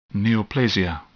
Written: neoplasia

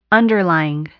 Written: underlying

